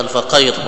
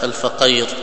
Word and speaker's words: الفقير: الفقير معنا بفتح القاف، يزيد بن صهيب الفقير وليس بكسرها، معنى ليس الفقير، وإن كان أنا نطقتها الفقير خطأ، وإنما بفتح القاف الفقير الذي معناه كما قلنا في فقرة زائدة.